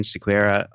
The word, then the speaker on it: Sequeira: Yeah, so I'm Julian Sequeira.